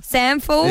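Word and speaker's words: SANFL: Yes, before 9 o'clock, Callan Caputo, he is a, how do you say it, SAFL? – Sanful. Yes.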